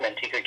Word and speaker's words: Manteca: I had the privilege of meeting both of them where they live in Manteca, I think it's pronounced Manteca, California. – Yes.